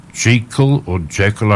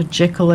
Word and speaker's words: Jekyll: Now, probably one of my best favourites is Gertrude Jekyll. I'm never quite sure how to pronounce her name. – I think most people call her Jekyll as in Jekyll and Hyde, don't they?